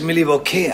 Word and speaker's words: Milwaukee: Eigentlich spricht man es Milwaukee aus, was auf Indianisch etwa heißt, das gute Land.